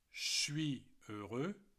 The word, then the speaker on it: heureux: Je suis heureux. Heureux. That's it. The H is not pronounced. And the X is not pronounced either.